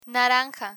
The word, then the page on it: naranja: Spanish